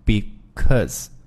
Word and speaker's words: because: 那么我们首先要注意一个这个就是因为这个单词，我们可能最熟悉的因为这个发音呢叫 because，是吧？但是我们这个在口语当中呢，会把这个单词的读音会。这个逐步的去啊简化，那么首先呢，把这个 because 它这个元音 o 的发音呢，给简化成发 a 这个发音，就是读成 because。